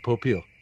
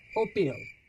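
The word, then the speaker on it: Popeil: How do you say it? – Popiel. – Popiel. – It's Pompeel.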